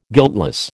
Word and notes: guiltless: American English

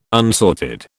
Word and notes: unsorted: British English

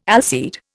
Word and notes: allseed: Indian

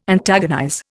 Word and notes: antagonize: Indian

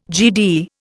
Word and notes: Gd: Indian

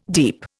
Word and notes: deep: Indian

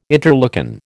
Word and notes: interleukin: American English

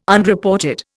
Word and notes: Unreported: Indian

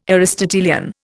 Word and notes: Aristotelian: Indian

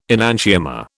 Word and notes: enantiomer: British English

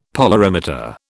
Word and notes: polarimeter: British English

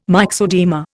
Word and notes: myxoedema: Indian

